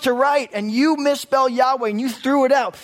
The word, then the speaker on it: Yahweh: The divine name, those four letters, Yod, He, Vav, He, which we now pronounce as Yahweh.